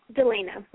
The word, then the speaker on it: Delena: Delena with a D.